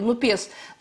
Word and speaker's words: НУПЕС: Действительно, левый блок, так называемый новый народный экологический и социальный союз, или НУПЕС, да, НУПЕС или НУПЕС, если, поскольку Франция, то, наверное, давайте сделаем ударение на последнем слоге, НУПЕС,